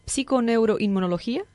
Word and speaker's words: psiconeuroinmunología: Em, bueno, los que no te conocen, vamos a repetir, eres eh, un, eh, experto en, esa palabra es bastante curiosa, psiconeuroinmunología, ¿lo dije bien? – Perfecto.